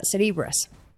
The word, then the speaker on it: Cerebras: Se escribe Cerebras como cerebro, pero se pronuncia Cerebras.